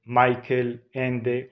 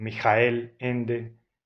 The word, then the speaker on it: Michael Ende: Entre los cuentos infantiles que he leído y que me han llamado mucho la atención y me han gustado especialmente, hay uno de Michael Ende, o Michael Ende, si lo pronunciamos en alemán, que es muy llamativo, muy simpático.